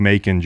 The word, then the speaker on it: Macon: I say bacon with an M. – And I said you should explain it like Macon, Georgia.